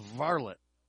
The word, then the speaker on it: varlet: rhymes with harlot, but it's called varlet. V-A-R-L-E-T.